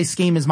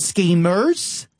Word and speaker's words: schemas: He developed these schemas because, and now I'm going to say schemas. My accent might, you know, kind of jolt this a bit because of being Australian. I'm going to spell it. It's S-C-H-E-M-A-S. – Schema. – So not schemers.